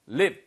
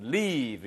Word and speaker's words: leave: 발음 길게 하셔야 돼요. 이거 live 이렇게 쓰시면은 넌 살 충분한 이유가 있어. 물론 그것도 멋진 문장이지만 여기선 leave 이렇게 길게 발음하셔야 떠나다고요. live 이렇게 발음하시면은 예, 바로 살다란 뜻이 되겠습니다.